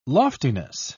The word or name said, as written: loftiness